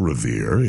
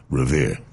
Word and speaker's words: Revere: You have to say it the way the locals say, Revere. It's difficult to learn that way, but it's not Revere, it's Revere.